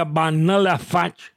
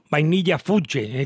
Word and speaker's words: Vanilla Fudge: Me dice Bobby que se pronuncia Vanilla Fudge. Son Vainilla Fuche, he comentado yo siempre, Vanilla Fudge, y que hacen versiones de los temas clásicos.